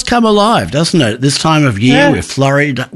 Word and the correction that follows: Floriade: How do you pronounce it? Floriade.